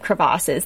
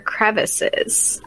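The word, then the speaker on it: crevasse: So I have to ask, is it pronounced crevasses or crevices? – I always have said crevasses, but maybe it's so fancy. – It does. – The way I say it, it's so fancy. When I say it, I'm like, because I said it in the Denali episode, I said crevices, and like, did I say it wrong, or are you just like really fancy? – No, I'm not really fancy. I think it's tomato tomato type of deal, but who knows.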